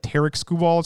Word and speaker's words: Tarik Skubal: they actually said Tarek Skubal is how they say it.